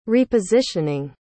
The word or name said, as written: repositioning